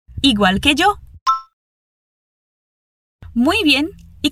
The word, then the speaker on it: igual: Quero que você preste atenção à pronúncia do L ao final nas palavras igual e el. É mais exagerado mesmo, sem fazer som de U.